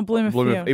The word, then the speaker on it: Blumenthal: So Heston Blumenthal, isn't it? – Oh, Blumenthal. – Even though it's spelt Thal.